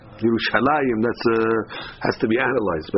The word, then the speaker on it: Yerushalayim: You'll never see it, very rarely in Tanakh, with a few exceptions, is there a yud in Yerushalayim. Although we pronounce Yerushalayim, it's a kariyuktiv.